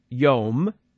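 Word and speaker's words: yom (יום): The word is yom. It's spelled in English, Y-O-M. Yam is the word for sea in Hebrew, not, like the ocean. Yom.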